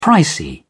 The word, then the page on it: pricey: British English